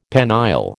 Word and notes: penile: American English

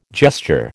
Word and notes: gesture: American English

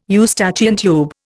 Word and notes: Eustachian tube: Indian

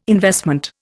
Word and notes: investment: Indian

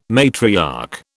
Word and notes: matriarch: British English

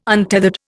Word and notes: untethered: Indian

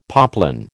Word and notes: poplin: American English

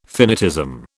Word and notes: finitism: British English

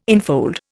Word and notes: enfold: Indian

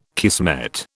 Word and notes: kismet: British English

